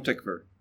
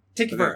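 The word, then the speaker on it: Tykwer: It's Tom Twyker. – Tom Tickver. – Tickver. Okay, that's how it is. Yeah, I never heard it pronounced.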